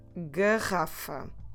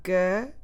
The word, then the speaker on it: garrafa: So the word for bottle is garrafa. Garrafa. Garrafa. Como se escreve garrafa? G-A-R-R-A-F-A.